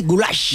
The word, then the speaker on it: goulash: ¿Qué pasa con el goulash? – Es que ni siquiera es una carne, es que ni siquiera es carne, es el goulash, es pronunciado así, goulash.